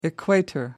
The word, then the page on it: equator: American English